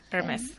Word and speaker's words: Hermes: Um, the uh, Hermes case, do you spell it, pronounce it that way? Yes, it's good, Hermes.